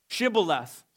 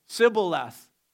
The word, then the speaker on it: Shibboleth: Shibboleth, Sibboleth. A wrong pronunciation was a dead giveaway. And, and one consonant, one missing consonant would be the difference between living and dying.